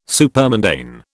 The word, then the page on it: supermundane: British English